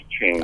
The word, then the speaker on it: Ching: Uh, the Tao Te Ching, it's, it's, pronounced Ching.